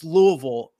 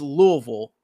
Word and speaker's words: Louisville: But yes, it's Louisville, and it is funny as somebody who's so immersed in college basketball and college athletics to hear others pronounce it incorrectly. It is Louisville, the situation that as of right now, as of 9:08 Eastern time, does not have a head coach.